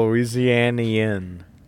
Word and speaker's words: Louisianian: And Louisianan, walk into a chat. – No, Louisianian. That's how you pronounce Louisianian.